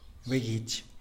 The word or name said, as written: begitx